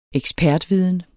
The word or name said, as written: ekspertviden